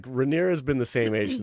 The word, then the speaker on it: Rhaenyra: I I was telling Jude, it's funny that like the actress who plays Rhaenyra, did I pronounce that right? Rhaenyra? – Yes. Yeah, you got it right.